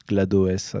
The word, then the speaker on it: GLaDOS: GLaDOS, GLaDOS, on peut prononcer un peu comme on veut, donc en fait est le truc qui nous dirige pendant tout le jeu.